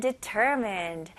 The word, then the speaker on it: determined: 네, D-E로 시작하지만 D가 아니고요. D. 약간 D-I처럼 읽으셔야 돼요. Determined. Mind. Mind가 아니에요. Mind. Determined.